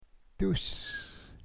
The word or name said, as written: duus